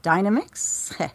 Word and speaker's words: Dynamix: Although, you know, funny enough, the Heart of China, that's a Dynamix. – Yes, that is the correct pronunciation. – Of course it is. It's nothing to do with the blender.